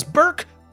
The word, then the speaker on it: Burke: because Burke rhymes with jerk.